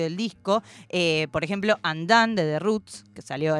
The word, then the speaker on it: Undun: Además, particularmente ese álbum que mega recontra recomiendo ir a escuchar, Andan, que se escribe Undun, digamos, mm.